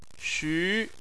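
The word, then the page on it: xu: tone 2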